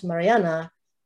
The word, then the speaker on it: Marianne: The song is So Long, Marianne, but everyone calls Mariana by the Norwegian pronunciation, including Leonard, always in referring to her.